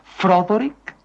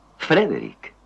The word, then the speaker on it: Frederick: ¿Dice usted también Froderick? No, Frederick. ¿Y por qué no es Froderick Frankenstein? ¿Por qué no? Es Frederick Frankenstein.